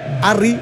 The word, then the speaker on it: Harry: 4 minutos primer tiempo, pero el 9 de Inglaterra, Harry Kane, conocido como Harry Kane, Harry, porque la H no se pronuncia.